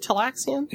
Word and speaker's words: Talaxian: Because, I mean, they're taking Kes's lung, which has nothing to do with the Talaxian. Did I say that right? Talaxian? – Yep.